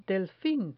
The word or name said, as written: delfin